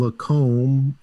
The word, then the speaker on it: Lacombe: Tiger Nation Tom at Tom Lacombe. Do I pronounce the E? – Obviously.